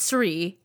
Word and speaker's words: Sri: It must be Sir. – No, it's Sri. He gave himself the title, Sri.